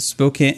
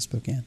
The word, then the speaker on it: Spokane: So that stretch between Spokane, Washington, I think that's how they pronounce it, Spokane, and Coeur d'Alene,